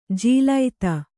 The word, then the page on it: ಜಲಧಿ: jaladhi